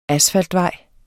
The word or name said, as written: asfaltvej